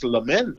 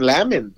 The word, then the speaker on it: lament: I remember going to an open mic and I didn't know how to pronounce lament. No, I was saying lamin.